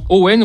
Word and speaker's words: Eoin: Owen, oui, c'est comme ça que ça se prononce, mais ça s'écrit E-O-I-N.